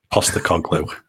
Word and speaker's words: Postecoglou: The coach as well, I don't know how to pronounce it, Ange Postecoglou, that's it. – Postecoglou, I think that's right.